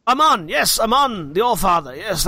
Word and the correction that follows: Annam: Anam. – Spelled the same way in my notes. – A-N-N-A-M. The Allfather. A-L-L-F-A-T-H-E-R.